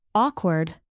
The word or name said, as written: awkward